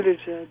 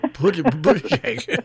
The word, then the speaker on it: Buttigieg: How do you pronounce his name? That's the first thing. – Buttigieg. – Yeah. Think of boot, ah, and JPEG. Buttigieg.